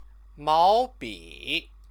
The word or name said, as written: maobi